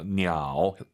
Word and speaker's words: 鸟: 삼성이 마지막 글자만 올리지 마세요. 냐오 하지만 사실은 보통 량지냐오 더 중국스럽게 발음하시려면 반삼성으로 딱 끝냅시다.